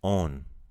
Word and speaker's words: on: So we have he is on. – On. – So it's spelled O-N, just like on, but we kind of make that O a long sound. On.